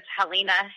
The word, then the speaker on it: Helena: And again, my name is Helena Hayes. So it's like Helena, Montana, but it's pronounced differently.